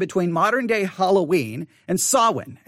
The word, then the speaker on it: Samhain: Samhain. – Samhain. All right. Samhain. Now, again, it's spelled S-A-M-H-A-I-N, Samhain.